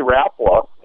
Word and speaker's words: Rapala: It's actually Rapala, and it's even Rapala worldwide.